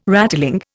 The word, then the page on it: rattling: Indian